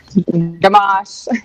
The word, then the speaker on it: Gamache: Gamache.